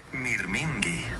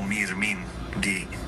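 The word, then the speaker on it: mirmingi (μυρμήγκι): Se dice mirmingi. Suena como mirmingi, más o menos.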